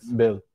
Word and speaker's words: Boehl: Is it correctly pronounced like this? Bill. Pedro Boehl. – Pedro Bill. Bill. Bill.